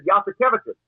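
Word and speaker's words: Jasikevičius: So our Spanish play-by-play guy, Publio Castro, said to me, hey, Billy, I don't know, how do you say this name? I said, Yasikevicius. He said, is it like, yes, we have cabbages?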